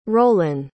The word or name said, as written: rollin